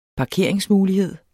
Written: parkeringsmulighed

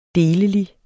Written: delelig

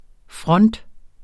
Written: front

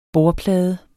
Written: bordplade

